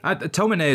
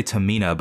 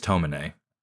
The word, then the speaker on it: Tomine: Tomine is what I say, but I've also heard people say Tamina, but I think it's Tomine.